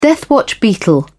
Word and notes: deathwatch beetle: British English

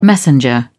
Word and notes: messenger: British English

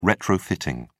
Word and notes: retrofitting: British English

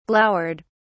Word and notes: glowered: British English

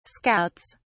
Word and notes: scouts: British English

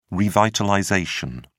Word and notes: revitalization: British English

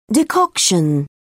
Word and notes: decoction: British English

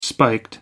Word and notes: spiked: American English

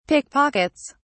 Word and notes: pickpockets: British English

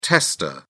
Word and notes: testa: British English

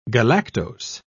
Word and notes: galactose: British English

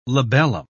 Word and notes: labellum: British English

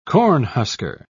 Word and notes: cornhusker: British English

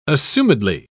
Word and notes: assumedly: British English

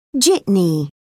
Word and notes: jitney: British English

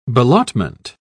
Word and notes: ballottement: British English

